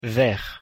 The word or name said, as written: vert